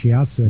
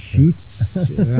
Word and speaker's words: Shiatsu: It's called, and I didn't even pronounce it right. I didn't know it was pronounced Shiatsu. It's called Shutsu.